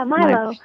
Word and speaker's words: milo: M-I-L-O, Milo, Milo? How do you say it? – Milo, yeah, Milo.